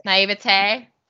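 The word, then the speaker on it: naïveté: I'm going to mispronounce it too. But naivety. – Naivete. – Naivete.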